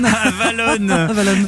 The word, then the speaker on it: Avalon: C'est vrai que j'ai prononcé Avalon comme Avalon en Bourgogne, hein, mais c'est pas grave. – Comme dans Lyon. – Avalon.